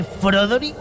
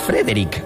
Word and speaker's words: Frederick: ¿Y también dice Froderick? – No, Frederick. – ¿Y por qué no es Froderick Frankenstein? – Porque no, es Frederick Frankenstein.